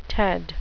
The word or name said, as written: ted